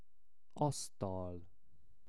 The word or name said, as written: asztal